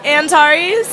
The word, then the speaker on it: Antares: B-E-C-C-A. And what's your name? – Antares. – Can you spell that? – A-N-T-A-R-E-S.